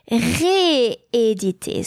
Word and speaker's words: rééditer: It's going to be not re, but ré. Why? Because éditer starts with a vowel, or I should say a vowel sound, and it's just for pronunciation reason. It's difficult to say to use a weak sound, rééditer, so a weak sound next to a vowel. It has to be stronger, rééditer.